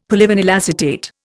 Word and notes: polyvinyl acetate: Indian

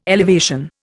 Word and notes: elevation: Indian

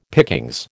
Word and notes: pickings: American English

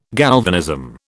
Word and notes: galvanism: British English

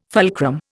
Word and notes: fulcrum: Indian

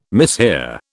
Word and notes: mishear: British English

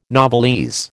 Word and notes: novelese: American English

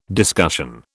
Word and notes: discussion: British English

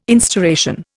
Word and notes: instauration: Indian

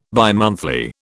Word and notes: bimonthly: British English

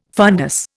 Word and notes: fundus: Indian

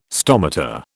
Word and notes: stomata: British English

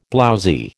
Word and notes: blowzy: American English

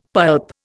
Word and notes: pulp: Indian

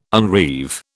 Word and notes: unreeve: British English

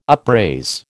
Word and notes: upraise: American English

